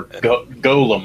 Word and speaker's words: Golem: I'm a big fan of retellings of the Gollum, you know, the Gollum myth. And that's pretty much what this is. – Yeah. – Mm-hmm. – Or Golem, I should say. I always mispronounce it.